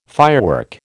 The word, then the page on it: firework: American English